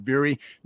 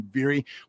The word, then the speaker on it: Vuori: In addition to my often discussed coat from Vuori, which is pronounced and spelled, I should say, V-U-O-R-I.